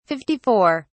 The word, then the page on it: 54: British English